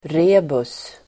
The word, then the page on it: rebus: [r'e:bus]